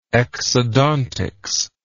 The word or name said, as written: exodontics